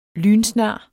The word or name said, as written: lynsnar